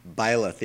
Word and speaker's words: Byleth: Byleth, yeah.